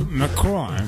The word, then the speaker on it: Macron: Los protagonistas principales serán el presidente de la República, obviamente, Emmanuel Macron, que no sé por qué lo pronuncio así, si es francés, ¿no? – Macron.